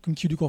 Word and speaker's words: Kim Ki-duk: on prononce bon, Kim Ki-duk en français, je sais pas pourquoi.